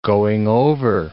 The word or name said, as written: going-over